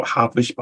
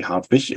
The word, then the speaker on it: Hartwich: Oh, Hartwich, by the way. All sorts of pronunciations in England and New Zealand of my name. I got used to all of them now, so I respond to all of these different versions. But it's actually Hartwich.